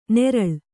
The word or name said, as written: ನೆರಳ್